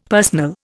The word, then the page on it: personal: Indian